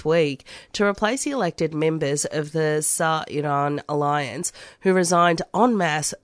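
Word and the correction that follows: Sairoon: Sairun. – Sairun.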